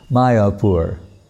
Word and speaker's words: Mayapur: And he sees this one land transfer, and the land is called, the location, it says, M-Y-A-P-U-R, like British saying, you know, M-Y for my, then A-P-U-R, Mayapur. Mayapur.